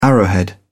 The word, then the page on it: arrowhead: British English